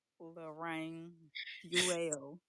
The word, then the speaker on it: Loraine Fuiell: Loraine Fuiell.